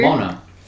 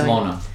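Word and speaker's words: Mjølner: Oh, no, it's just M-J-O-L-N-E-R. – What? – M-J-O-L-N-E-R. – That's the M-J-O. – Mona. – Are you sure? – The J will be silent, yeah. – M-J. – Silent. – The J is silent.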